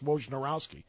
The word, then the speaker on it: Wojnarowski: His own family does not say it the way it's spelled. They just say it as if there's a couple of silent letters in there, and it makes it easier to say Wojnarowski.